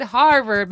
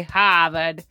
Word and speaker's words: Harvard: You even said it like a Boston person. It's Harvard, but they say Harvard.